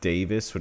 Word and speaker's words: Davies: So Davies is pronounced Davis, which I can live with, but what I couldn't live with is I'm calling him George.